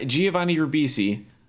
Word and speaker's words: Giovanni Ribisi: Giovanni Ribisi. Wow. I said it right that time.